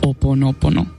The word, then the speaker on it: Ho'oponopono: Ho'oponopono, sí, lo he dicho bien.